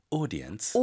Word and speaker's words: audience: Audience. – Audience. 선생님, 그리고 그런가? Audience 해도 알아듣는다고요? – 영국에서는 그래요. 영국 발음은 이 AU가 O 발음이거든요.